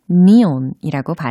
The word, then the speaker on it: neon: 철자는 N-E-O-N e 이라는 철자예요. 아, 네온? 네, 네온 사인 많이 들어보셨죠? 그게 영어로는 니온이라고 발음을 하셔야 돼요.